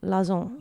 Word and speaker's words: Lauzon: L-A-U-Z-O-N. – Lazon.